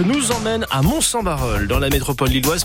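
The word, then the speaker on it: Barœul: Pourquoi Eh bien pour nous apprendre, ou plutôt pour apprendre aux personnes du sud du PH d'Arras à bien prononcer Barol. Eh oui, il n'y a pas de I, et ça il va nous le dire comme il se doit.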